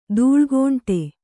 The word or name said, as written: ದೂಳ್ಗೋಂಟೆ